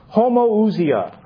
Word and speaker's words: homoousia: The word is homoousia. I'll spell it for you. Again, I don't have a transparency. Homoousia. And this is Greek. H-O-M-O-O-U-S-I-A. H-O-M-O-O-U-S-I-A. Homoousia. It's from two Greek words.